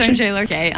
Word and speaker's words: Sondre Lerche: Sondre Lerche? – Oh, yeah, Lerche? – Sondre Lerche, uh-huh.